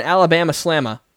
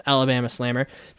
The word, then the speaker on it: Alabama Slammer: And now we got an Alabama Slamma. That would be how you say it in New England, but it's Alabama Slammer.